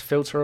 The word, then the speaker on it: philter: Um, I believe it's pronounced "filter of love." Is that is that f- Sound like. Is it filter of love or filter of love? P H I L T E R.